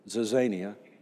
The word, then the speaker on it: Zizania: Zizania is the word. We pronounce it that way in English, not in Greek. Zizania.